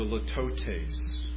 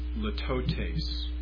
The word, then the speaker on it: litotes: A tenth form of figure of speech is the litotes. Litotes. L-I-T-O-T-E-S. Litotes. Somebody wants to correct my pronunciation on that, they're welcome to do that. That's how I've always pronounced it.